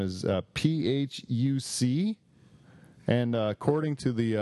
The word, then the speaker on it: Phuc: it's not fuck, it's fook, right? So then the Vietnamese pronunciation of that is Phuc, not fuck.